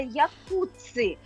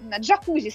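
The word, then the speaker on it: Jacuzzi: Значит, друзья мои, имя автора, человека, который придумал вот это самое устройство, эту самую конструкцию, это итальянец Кандида Якуцци. Но неправильное произношение, да, на американский манер, он джакузи стал. Если мы с вами прочитаем вот это самое Якуцци, да, в американском варианте, то получится джакузи.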